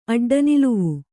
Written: ಅಡ್ಡನಿಲುವು

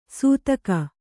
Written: ಸೂತಗ

